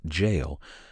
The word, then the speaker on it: gaol: The Old Stone Jail, usually spelled G-A-O-L, but pronounced jail,